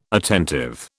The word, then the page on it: attentive: British English